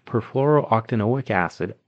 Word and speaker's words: perfluorooctanoic acid: In the late 1990s, attorney Robert Billott filed a lawsuit against DuPont alleging chemical waste, specifically perfluorooctanoic acid.